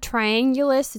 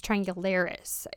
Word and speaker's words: triangularis: It's not triangulus, it's triangularis, I think.